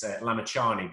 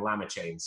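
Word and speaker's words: Lamichhane: where they used to mispronounce his name because it's Lamachani, but they used to call him Lamachain.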